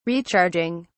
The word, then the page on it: recharging: British English